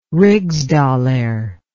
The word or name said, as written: rigsdaler